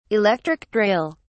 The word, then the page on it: electric drill: British English